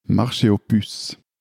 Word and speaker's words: Marché aux Puces: Wie wird denn ausgesprochen? – Marché aux Puces.